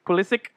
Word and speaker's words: Pulisic: Pulisic.